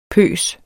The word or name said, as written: pøse